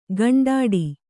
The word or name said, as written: ಕುಂಟಾಟ